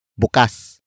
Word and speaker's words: bukas: Buksan comes from the root word bukas. Bukas. Bukas, which is spelled as B U K A S.